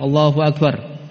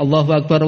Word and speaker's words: Allahu Akbar: Sehingga dibacanya enggak berhenti, bukan Allahu Akbar. Allahu Akbar, nah, berhenti kan? Bacanya Allahu Akbar, Allahu Akbar. Bersambung sehingga dikatakan ganjil.